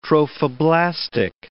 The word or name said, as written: trophoblastic